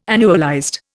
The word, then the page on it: annualized: Indian